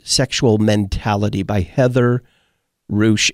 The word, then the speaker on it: Ruesch: The name you're looking for there is Heather Ruesch, and it is spelled, Jeff, correct me if I'm wrong, it is R-U-E-S-C-H, pronounced Ruesch.